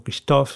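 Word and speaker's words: Kristof: Nos referimos a Agatha Christoph, eh, o Christoph, si hacemos la pronunciación afrancesada,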